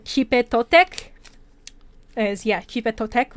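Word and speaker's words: Xipe Totec: And the last one is pronounced Chipe Totec, which means transformation.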